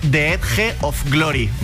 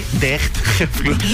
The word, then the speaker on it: The Edge of Glory: Y ahora nos vamos a por Lady Gaga con The Age of Glory. Madre mía, qué cuadro de pronunciación. Soy un cuadro hablando inglés, así que mejor lo voy a decir como se escribe: The Edge of Glory.